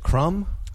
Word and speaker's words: Krum: Is it Krum? – It's Krum, yes.